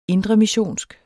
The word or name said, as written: indremissionsk